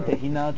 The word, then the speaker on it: tahin: Un autre ingrédient que moi j'aime beaucoup, c'est le tahin. – Le quoi? – Le tahin. Tahin, tahina, tu peux le prononcer dans toutes les langues que tu veux. Ça s'écrit T-A-H-I-N en français.